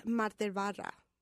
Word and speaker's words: Materva: How do you say it again? – It is Materva.